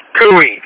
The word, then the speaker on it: GUI: I pronounce it GUI, but it's G, it's an acronym, Graphic User Interface.